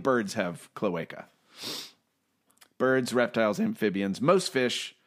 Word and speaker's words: cloaca: By the way, we were mispronouncing it. It's cloaca.